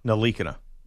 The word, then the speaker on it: Ntilikina: Ntilikina. – Yeah, Ntilikina. Yeah. – Ntilikina. – That's what I said. – Frank Ntilikina. Sorry. – Frank, you screwed me up now. – It's Ntilikina? – Frank Ntilikina.